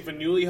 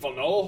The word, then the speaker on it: Faneuil: For long as I'm calling it, I was saying Faneuil Hall, and it's like, you mean Faneuil Hall? And I'm like, what the? – Faneuil.